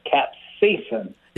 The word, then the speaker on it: capsaicin: This is how she told me to pronounce it. It's called capsaicin. – She was right, absolutely.